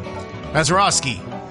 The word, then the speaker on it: Mazeroski: Can't say his name. Mazeroski. There we go.